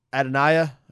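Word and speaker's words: Adonijah: Adoniah.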